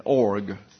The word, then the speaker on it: Og: And later over two kings of the Amorites, Sihon and Og, is the way you pronounce his name.